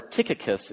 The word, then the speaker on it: Tychicus: Tychicus, or Tychicus is as it's supposed to be pronounced, Tychicus will tell you all the news about me.